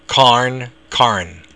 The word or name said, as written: carn